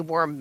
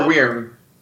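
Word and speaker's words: wyrm: But at least I got a nice shiny worm mount. – Worm or weirm? – Didn't know that it had two different pronunciations.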